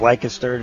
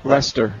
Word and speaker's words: Leicester: He's gone to the University of Leicester. Did I say that right? – Leicester. – University of Leicester. – I didn't say it right then.